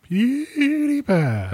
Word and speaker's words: PewDiePie: You said it wrong. – Did I? – Yeah. – Is it PewDiePie? Like say it. – PewDiePie. – There you go.